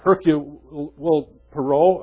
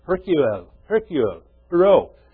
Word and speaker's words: Hercule Poirot: Everything that's ever passed across these, as Hercule Poirot, or did I say it? I can never get his name right. Hercule Poirot. The little gray cells.